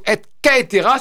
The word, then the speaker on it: et caetera: ou plutôt et caetera, si on prononce comme cela doit l'être.